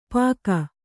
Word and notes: ಪಾಕ: kannada; pāka

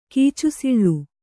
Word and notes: ಕೀಚುಸಿಳ್ಳು: Kannada

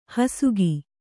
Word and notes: ಹೊಸಕು: hosaku

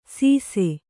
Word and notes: ಸೀಸೆ: Kannada

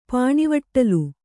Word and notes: ಪಾನಿವಟ್ಟಲು: pānivaṭṭalu

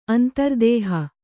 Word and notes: ಅಂತರ್ದೇಹ: Kannada

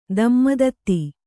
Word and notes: ದಮ್ಮದತ್ತಿ: damma datti

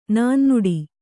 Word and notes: ನಾನ್ನುಡಿ: nānnuḍi

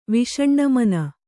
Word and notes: ವಿಷಣ್ಣಮನೆ: viṣaṇṇa mane

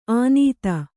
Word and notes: ಆನೀತ: ānīta

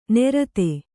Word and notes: ನೆರತೆ: Kannada

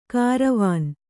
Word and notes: ಕಾರವಾನ: kāravāna; kannada

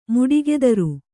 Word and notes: ಮುಡಿಗೆದರು: muḍigedaru

